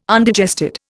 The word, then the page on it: Undigested: Indian